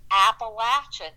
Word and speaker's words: Appalachian: And there was somebody up on stage, and they said there's two different pronunciations or a bunch of them for the word Appalachian. And they said Appalachian. And I loved under your breath you said Appalachian. – And I do. I do it. My daughter does it. My whole family does. It's our little civil disobedience because I am staunchly loyal that it is Appalachian. And, you know, mainly because I think a body should pronounce a place the way the people that call it home pronounce it.